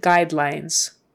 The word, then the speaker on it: guidelines: The, forget the U. It's a long I. Guide lines. So two long I's in those two syllables. Guidelines. And the S at the end is a Z.